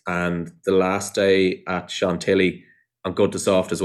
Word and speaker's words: Chantilly: Chantilly.